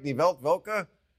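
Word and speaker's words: Weltwoche: All right, she's a columnist for the Swiss weekly magazine Die Welt Woche. Amy Holmes, did I say that right? Die Welt Woche? – Excellent pronunciation.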